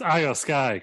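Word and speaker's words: IYO SKY: Because so it's pronounced Io Sky, because it's all capitalized.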